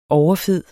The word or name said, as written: overfed